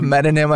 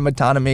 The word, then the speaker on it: metonym: I said instead of metonym, I said metonymy because I was reading off my slides the other day.